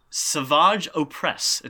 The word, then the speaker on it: Savage Opress: Savage Opress. It's how it's pronounced, actually.